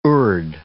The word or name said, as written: urd